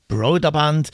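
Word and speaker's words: Brøderbund: Karateka ist ein Martial Arts Kampfspiel aus dem Jahr 1984, gepublished von Broderband. Ja, das ist die korrekte Aussprache. Broderband.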